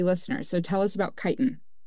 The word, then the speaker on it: chitin: Is it chitin? Chitin? – Chitin. – Chitin. Okay.